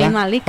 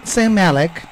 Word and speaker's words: Zayn Malik: Zayn Malik. – Okay, ¿se lo dije bien? – Sí. – Zayn Malik.